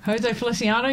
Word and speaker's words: Jose Feliciano: It's from Jose Feliciano. I said that right. – Jose Feliciano, yep.